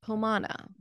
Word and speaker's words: Pomona: I think you pronounce her name Pomona. Pomona. Like Pomona. – Moana, but Pomona.